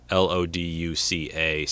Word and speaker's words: LoDuca: And I think I goofed up when I was talking about the music and how you say it's not Joseph DeLuca, it's Joseph Loduca, Loduca.